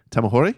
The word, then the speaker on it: Tamahori: It's Once Were Warriors, directed by Lee Tamahori.